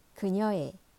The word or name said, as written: 그녀의